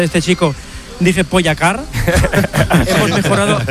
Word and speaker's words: Pogačar: Nos dijeron que están pronunciando el nombre de Pogačar de mil maneras distintas, así que os voy a dejar cómo se dice, porque aquí dice, Juanma dice que es aguda, mientras que otros es drújula, así que aquí tenemos cómo se dice de verdad.